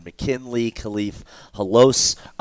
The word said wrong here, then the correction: Halasi: It's Verone McKinley and Khalif Halasi. They're kind of hard to pronounce.